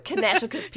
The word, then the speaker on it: Connecticut: And my dad was like, no, it's a silent C. Sorry. – These books remain the only reason that, like, I know anything about Connecticut.